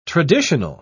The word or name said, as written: traditional